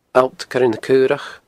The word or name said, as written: Allt Coire na Caorach